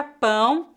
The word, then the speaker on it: pão: A palavra pão, com som nasal, é o nome de um alimento feito com farinha. A pronúncia dessa palavra é feita com o ditongo nasal.